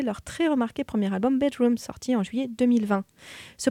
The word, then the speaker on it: BDRMM: c'était le groupe post-punk shoegaze britannique Bedroom, qui s'écrit donc BDRMM, avec le titre It's Just a Bit of Blood.